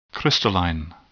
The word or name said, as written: crystalline